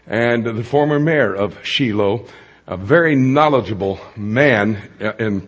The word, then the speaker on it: Shiloh: They don't pronounce it Shiloh in Israel, it's Shiloh.